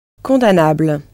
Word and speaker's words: condamnable: Condamnable. – Good. Condamnable. Spelt with the same M-N as in English, but you can't hear the M in French.